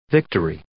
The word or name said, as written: victory